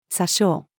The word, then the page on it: 詐称: female